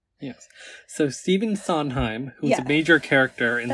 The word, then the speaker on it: Sondheim: Sondheim, yeah. – That's wrong. – It sounds wrong. I don't know how to say it. Sondheim. The D is silent.